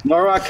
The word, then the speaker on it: Norwalk: Noel's tour takes him to Norwalk. Am I saying that right? Norwalk or Norwalk? – Yeah, Norwalk, Connecticut.